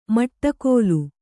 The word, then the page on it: ಮಟ್ಟಗೋಲು: maṭṭagōlu